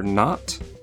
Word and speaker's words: Knot: The first is a very short game called Answer Not, but Not is spelled K-N-O-T.